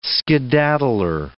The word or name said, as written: skedaddler